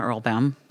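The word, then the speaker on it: Erlbaum: It rhymes with my last name, Earl Baum.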